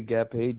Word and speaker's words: Gapay: My name is Jerry Gapay, G-A-P-A-Y.